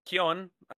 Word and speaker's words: Keoghan: I learned how to pronounce his name, Keoghan, not Keoghan or something. It's a little Irish, but I could not find a clear picture of him in the Joker makeup from the Batman movie.